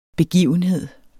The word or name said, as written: begivenhed